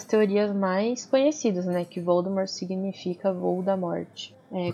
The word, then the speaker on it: Voldemort: Voldemort se pronuncia sem o T no final. Que uhum. – isso uhum. em, na pronúncia francesa é um clássico. Uhum. É, então, Voldemort, é Voldemort.